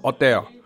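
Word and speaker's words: hôtel: 那我说那个 Hotel 的，他说 Hotel。就是 Adele，就是那 H 不发音这样，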